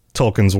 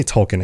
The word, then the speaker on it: Tolkien: And that makes a lot of sense because there are a lot of themes in Tolkien's works, right? And I say Tolkien, it should be Tolkien, but please forgive me on any of my pronunciations.